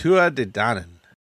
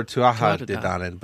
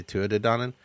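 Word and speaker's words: Tuatha Dé Danann: It's Tua De Danann. – What is it? – Tua De Danann. – Oh, you looked up how to pronounce stuff. – Yeah, or Tuatha De Danann.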